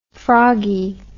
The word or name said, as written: froggie